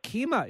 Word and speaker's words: keema: I don't know what keema is, though. Can you tell us what that is and how it works with the aubergine, the eggplant? – Yes, your pronunciation was perfect, actually.